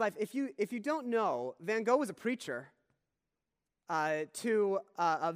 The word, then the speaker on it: Van Gogh: And they go back to the time and the life of Vincent van Gogh. And they meet this painter. And by the way, in English, in British culture, it's pronounced Van Gogh.